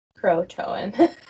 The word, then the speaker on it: Croatoan: Croatoan or Croatian? – Croatoan. It's spelled very similarly.